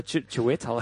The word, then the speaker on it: Chiwetel: Okay, the name also, Chiwetel.